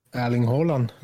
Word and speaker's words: Erling Haaland: Erling Haaland.